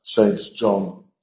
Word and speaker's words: St. John: Notice we pronounce St. John St. John. Okay, that's how you pronounce it, I'm afraid. Yeah, sorry. It's just one of those silly English names.